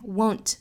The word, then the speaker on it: won't: Won't 尾部 t 不发了，那我们可以发成 won't even try，这样发音是比较多见的。